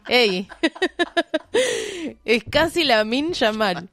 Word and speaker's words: Lamine: Lamil. – Lamil. – Sí, Lamin Yamal. – Lamin. – Lamin. Lamin. Lamine se escribe.